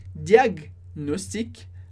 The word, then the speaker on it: diagnostic: Lasagne, que a gente tem o GN como ny, ou também champagne, champagne, que seria a regra geral da pronúncia do GN. No caso de diagnostic, a gente separa essa sílaba e fica diagnostic.